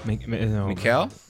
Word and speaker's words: Mikhail: Mikhail, like Mikhail Gorbachev, right? – Mikhail. There is less of K and more of H. – More of a H. – Harassment. – Yeah, Mikhail.